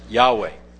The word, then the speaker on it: Yahweh: It's actually not spelled with the A and the E. It's just YHWH. But in order to pronounce it, they've added an A and an E so that we can, in English, pronounce it Yahweh.